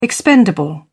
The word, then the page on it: expendable: British English